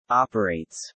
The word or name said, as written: operates